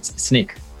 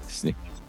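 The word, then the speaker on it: Snyk: Snick? – I am, yeah. That? It's always a problem pronouncing that one, so I know where it's Sneak. – Sneak.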